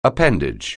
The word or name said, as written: appendage